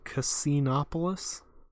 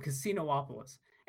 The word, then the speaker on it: Casinopolis: No, Casinoopolis. And like that is actually how you say it. But there's only one O? There's only one O. But that is actually how you say it because in later Sonic games, characters actually say that name and reference it and they say Casinoopolis.